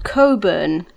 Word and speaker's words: Cockburn: And unfortunately, we have a suburb in Western Australia called Coburn, but it's spelt as Cockburn.